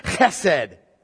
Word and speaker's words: chesed: Chesed is how you pronounce it. I'm going to say chesed the rest of the way because it kind of hurts to go chesed. And I'm not a Hebrew. You can spell it H-E-S-E-D if you want. That's the way, or C-H-E-S-E-D if you want. It's chesed.